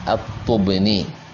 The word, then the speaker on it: At-Tubni: Menukil Sahibu Tertib Al-Madarik dan lainnya, Al-Ikmalul Mu'lim dan lain-lain, Al-Qadhi Iyad rahimahullah menukil dari Abi Marwan At-Tubni. At-Tubni bidhammil muhmala, yani at-ta' didhammakan, wa sukunil muwahhada, yaitu dan disukunkan yang bertitik satu, yaitu ba, thumma nun, kemudian setelahnya nun. Jadi bacanya At-Tubni, yaitu Abu Marwan, Abdul Malik, Ibn Ziyadatillah, Ibn Ali, Ibn Al-Husain, Ibn Muhammad, At-Tamimi, thumma Al-Himmani.